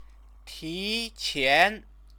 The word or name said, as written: tiqian